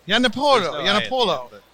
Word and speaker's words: Giannopolo: Did I say it right? – Giannopolo. – It's Italian.